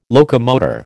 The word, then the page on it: locomotor: American English